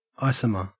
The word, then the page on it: isomer: Australian English